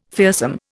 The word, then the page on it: fearsome: Indian